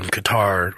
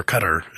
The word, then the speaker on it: Qatar: The other one, not knowing much about the area, but when Qatar – or Qatar, I think is the proper way. I always say that incorrectly.